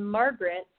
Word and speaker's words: Margret: And then Margaret, spelled M-A-R-G-R-E-T.